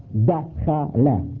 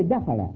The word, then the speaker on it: dakhala: The Arab pronounce entered like when in that door, dakhala, dakhala, dakhala. They say dakhala, you see?